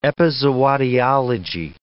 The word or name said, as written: epizootiology